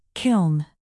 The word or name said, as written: kiln